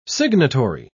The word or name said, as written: signatory